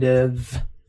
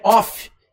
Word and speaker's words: of: They don't allow this kind of protest. Muito importante, pronúncia dessa palavrinha aqui, ó. Não é off, e sim Of.